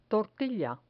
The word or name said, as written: tortilla